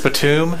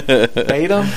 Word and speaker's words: Batum: His last name is spelled B-A-T-U-M. I was like, Batum? Batum? – No. Nope. – Okay. – Batum. – And then someone's like, Nicholas Batum, and I'm like, I was not even close.